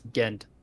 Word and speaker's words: Ghent: So I had to ask, I was like, how do you pronounce this? And they're like, that's Ghent. I was like, oh, okay, that's how you say it.